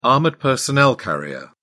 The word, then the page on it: armoured personnel carrier: British English